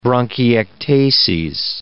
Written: bronchiectases

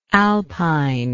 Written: alpinely

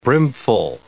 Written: brimful

